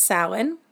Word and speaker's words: Samhain: Even though it's spelled Samhain, it's Samhain, which translates directly to summer's end.